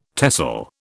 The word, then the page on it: TESOL: British English